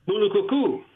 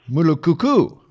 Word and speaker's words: Mulukuku: Help me if I'm pronouncing this correctly. In Mukuluku, you know, Nicaragua, is that the name? – Mulukuku. – Mulukuku. Okay.